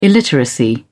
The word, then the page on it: illiteracy: British English